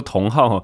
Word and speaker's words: 酮好: 今天这集呢，我们来专访脸书社团同好的创办人沙锦贤 James。那这个脸书社团的名字呢，叫做同好哦。这个同是生酮饮食的同，那那个好呢是好不好的好。那我听 James 讲哦，他的发音叫做同好哈，相当于这个各式各样的人有共同嗜好嘛哈。